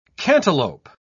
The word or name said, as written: cantaloup